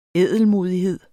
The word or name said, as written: ædelmodighed